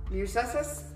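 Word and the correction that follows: Mušḫuššu: Masusu. – Masusu? – You added a couple extra syllables, love. I am sorry to inform you. Masusu. Say it with me now.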